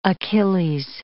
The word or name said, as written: Achilles